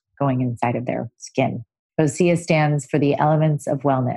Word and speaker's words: Osea: Today's episode is sponsored by Osea Malibu, the original plant-based results-driven skincare line. Osea, pronounced O-C-E-A,